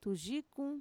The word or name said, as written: tuyikun